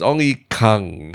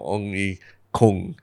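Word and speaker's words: Ong Ye Kung: Ong Yi he says it's Ong Yi Kang, not Ong Yi Kung, even though everybody wants kung fu fighting, but it's Ong Yi Kang.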